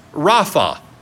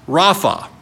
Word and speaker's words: Rapha: But again, it's identified, or he is identified, as R-A-P-A, which in our tongue is pronounced Rapha. Rapha.